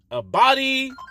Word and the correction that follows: Abdi: I said his first name wrong. Excuse me. Abdi Bashir Jr.,